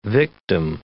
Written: victim

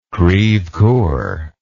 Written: crevecoeur

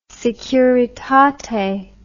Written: Securitate

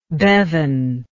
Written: Bevan

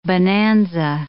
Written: bonanza

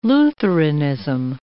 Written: Lutheranism

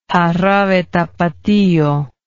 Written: jarabe tapatio

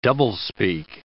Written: doublespeak

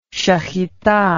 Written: shechitah

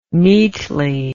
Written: meetly

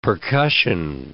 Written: percussion

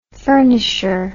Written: furnisher

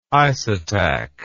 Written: isotach

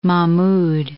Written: Mahmud II

